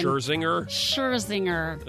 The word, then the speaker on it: Scherzinger: Nicole Schlesinger. – Robin Thicke. – Nicole Schlesinger. – I can never say her last name. – Scherzinger. It's not even close to Schlesinger.